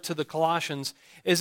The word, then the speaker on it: Colossians: Colossians chapter 1.